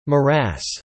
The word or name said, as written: morass